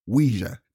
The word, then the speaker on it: Ouija: I think you pronounce Ouija very funny. That's what I think. – Funny about that. How do you pronounce it? – Ouija.